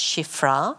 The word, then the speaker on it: Shiphrah: Then the king of Egypt, Pharaoh, spoke to the Hebrew midwives, of whom the name of one was Shiprah. Now it's actually pronounced Shiphrah.